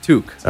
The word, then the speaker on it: toque: Is it pronounced took or toque? – Toque.